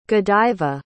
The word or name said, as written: godiva